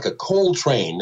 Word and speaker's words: Coltrane: Mr. Coltrane. And I forget if it was my dad or my older sister who said, no, it's like the rail yard, like a Coltrane.